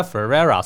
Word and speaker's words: Ferrera: 嗯，那但是这个女孩子，这个女人叫做 Gloria，就是由 America Ferrera。 – 你还是念成法拉利了。 – America Ferrera 所饰演的 Gloria 哦，那她在访谈里面刚刚也有提到说，她在一开始的时候也的确会得要先去理解说，这个人这个女人是谁。